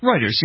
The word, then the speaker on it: Reuters: Reuters, yeah.